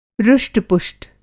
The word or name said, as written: रुष्टपुष्ट